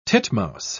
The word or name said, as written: titmouse